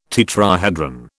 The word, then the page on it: tetrahedron: British English